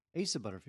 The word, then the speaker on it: Asa: I think, is it Asa or Aza Butterfield? How do you pronounce his name? – Asa Butterfield.